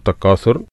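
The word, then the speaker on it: تكاثر: സൂറത്തു തക്കാസുർ, അതിലെന്തൊക്കെയാണ് നിയമങ്ങൾ നോക്കാം. അൽഹാക്കുമു തക്കാസുർ, തക്കാസുർ അഥ അലിഫ് ബ ത ഥ അക്ഷരാണ്. അപ്പം ശരിക്ക് ഉച്ചാരണം ശ്രദ്ധിക്കുക. തക്കാസുർ സ എന്ന് ഉച്ചരിക്കരുത്.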